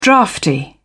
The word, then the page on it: draughty: British English